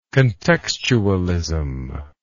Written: contextualist

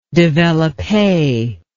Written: developpe

